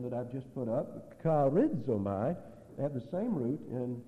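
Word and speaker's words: charizomai (χαρίζομαι): This word, there is another word in the New Testament that looks like this, and it's pronounced charizomai, C-H-A-R-I-Z-O-M-A-I.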